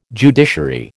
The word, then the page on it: judiciary: American English